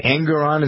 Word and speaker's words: Engoron: Engoron, is that how you pronounce his name? – I think it's right.